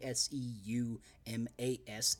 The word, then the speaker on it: Seumas: Check out at the Seumas, which is spelled funny S E U M A S Seumas um, on Twitter.